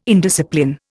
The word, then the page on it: indiscipline: Indian